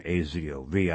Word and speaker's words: Azov: AZOV, I've been mispronouncing that, Azov, was a Nazi organization.